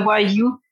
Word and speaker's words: yu: So it's like you learn, except it's learn you, and the you is spelled Y-U. because that's the Chinese language, the Chinese word for language.